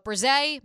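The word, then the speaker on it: Bresee: Clemson's Miles Murphy and then Brian Breesy. – Brise. – I'm going to keep mispronouncing it.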